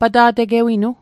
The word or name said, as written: pata'tegewinu